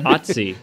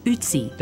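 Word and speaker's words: Ötzi: Etsy, Ertsy, Utsi. – Even though some of us can't really pronounce that name. – Otsi. – Spell it. – O with two dots on top. – Scientists call him Utsi.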